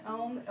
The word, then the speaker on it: Om: So when we chant Om, Om is actually made up of three different sounds. It's an ah sound, an oo, a u long u sound, and a mm sound.